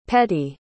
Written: petti

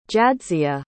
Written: jadzia

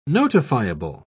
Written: notifiable

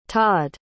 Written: todd

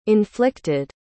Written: inflicted

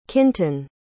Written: kintan

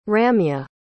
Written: ramya

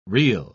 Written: reel

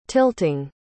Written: tilting

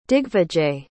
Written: digvijay

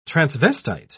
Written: transvestite

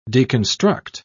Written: deconstruct

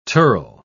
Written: tirl

